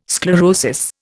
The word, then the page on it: sclerosis: Indian